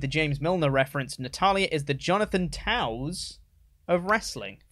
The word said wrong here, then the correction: Toews: It is pronounced Taves. – Taves? – I'm not going to blame you for that one, because T-O-E-W-S probably shouldn't be pronounced Taves.